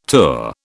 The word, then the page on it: tour: British English